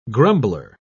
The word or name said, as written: grumbler